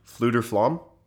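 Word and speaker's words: Fflewddur Fflam: Bard, Fluder Flam. – F F L E W D D U R space F F L A M. – Fluder Flam.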